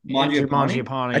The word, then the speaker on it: Mangiapane: Mangiapane.